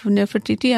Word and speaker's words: Nefertiti: And I think when they did the analysis of Nefertiti, am I saying her name right? Yeah.